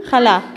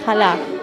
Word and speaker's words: Challah: Ja, Challah, genau, richtig, Challah. Chalot ist die Pluralform in Hebräischen. – Okay. Challah ist die Einzahl. – Genau. – Also Challah heißt das, habe ich es gut ausgesprochen? – Sehr gut, ja, richtig.